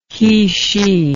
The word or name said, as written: he/she